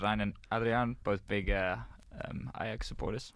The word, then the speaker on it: Ajax: battle with I'm going to say Ajax because my uncultured New Zealand upbringing would lead me to do so. How do you say that again? – Ajax.